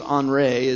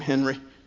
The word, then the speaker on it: Henri: His name's Henri. It's pronounced that way, European, but I'll call him Henry because I'm from Ohio.